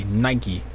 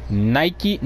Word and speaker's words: Nike: And somebody was like, why don't why don't we pronounce Nike? No, why don't we pronounce bike like Nike? Bikey. Nike. or or just Nike cuz in the UK they say Nike, Nike bike. But we say Nike and bike.